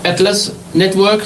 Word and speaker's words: Atlas Network: De esta red, de esta ofensiva de la derecha, hay una red, como he dicho, el Atlas Network, se dice así, ¿ya? En inglés, y también otros grupos de derecha en Europa y en Estados Unidos que prácticamente desarrollan estas teorías, ¿ya?